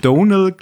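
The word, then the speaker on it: Domhnall: Das M spricht man nicht. Richtig. Donald Gleason. Hm. Weil der hat nämlich so ein YouTube-Video ge gepostet äh, oder irgendwie ist es veröffentlicht. Da hat er gesagt: The M is only there to confuse the Americans.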